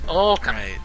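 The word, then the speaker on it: Ōkami: Well, they have a dash over the O. – It says Okami. – It basically has a dash over it. It's a long O. So it's Okami.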